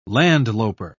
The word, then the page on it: landloper: British English